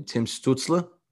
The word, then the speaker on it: Stützle: I personally think, and I'm going to butcher his name, Tim Stützle. – Yeah. – Is that how you say it? – Well done.